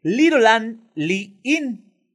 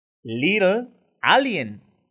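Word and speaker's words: Little A'Le'Inn: Una vez ahí, la clave para llegar al área 51 está en el hotel, motel y restaurante Little and Inn. Little Alien, pronunciado de otro modo, que antes se llamaba Rachel Bar and Grill.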